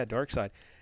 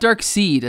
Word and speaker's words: Darkseid: Yeah, Darkseid. Now, not Dark Seed, as some people have incorrectly said. – Yeah, it's spelled S-E-I-D there at the end.